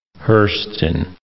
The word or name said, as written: Hurston